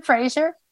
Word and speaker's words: Fraser: Brendan Fraser. Who, is that how you pronounce his last name? – Oh, yeah.